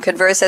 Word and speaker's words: Converse: Converse as in conversation, correct? – You are correct.